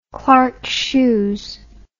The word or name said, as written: clarks shoes